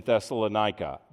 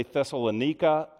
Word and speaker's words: Thessalonica: And so it is with the fledgling church in Thessalonica. That's how I'm going to pronounce it. There are a few people that say Thessalonica, but I grew up saying Thessalonica, and I can't say the other.